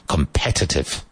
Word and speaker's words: competitive: Competitive. competitive. Emphasis on the second syllable.